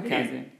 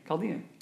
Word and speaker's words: Chaldean: How do you pronounce that? – Chaldean. – Not Kazdian. Chaldean.